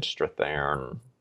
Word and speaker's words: Strathairn: But David Strathairn.